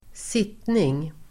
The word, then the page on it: sittning: [²s'it:ning]